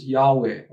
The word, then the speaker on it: Yahweh: We've inserted A and E, and we pronounce it Yahweh or Yahweh.